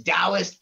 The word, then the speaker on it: Taoist: It's it's Taoist professor, but it's spelled with a T.